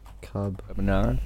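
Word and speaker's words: Kabaneri: Carbonari. – Carbonari.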